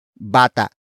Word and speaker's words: bata: And that main topic is bata. Bata. Bata. Bata is spelled as B-A-T-A.